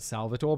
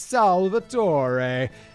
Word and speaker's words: Salvatore: Because I just want to say that Salvatore, but apparently in Italian it's Salvatore.